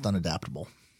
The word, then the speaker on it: unadaptable: Yeah, with a slash between un and adaptable, but it's just pronounced unadaptable.